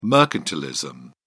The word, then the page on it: mercantilism: British English